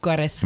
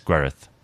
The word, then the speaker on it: Gwared: Gwared. – I'm probably pronouncing that wrong. Because the double D is actually th in Gwareth. – In Welsh, so it's Gwareth.